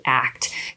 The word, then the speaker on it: ACT: So it's often used as, it's an acronym, so ACT, but you pronounce it ACT.